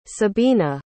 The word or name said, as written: sabina